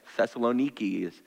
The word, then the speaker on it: Thessaloniki: Thessaloniki is how it's pronounced today.